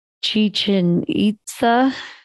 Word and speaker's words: Chichen Itza: Chichen Itza.